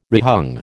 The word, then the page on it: rehung: American English